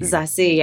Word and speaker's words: Zazie: Zazie, I've been practicing all morning. I've been saying it wrong for years. Did I do that right? Zazie. – Zazie, yeah.